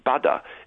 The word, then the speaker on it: Bada: They're calling, I believe it's pronounced Bada, it's the Korean word for ocean, but that's their own proprietary operating system, and that's where all their resources are going to start going in future.